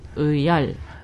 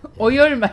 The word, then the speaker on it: 의열: 어, 혈이 아니고 열. – 의열. – 네, 의열. 예, 제가 잘 안 됩니다, 발음이. – 어열 말고 예.